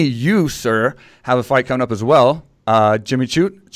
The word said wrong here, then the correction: Crute: is how you say his last name? – Crute.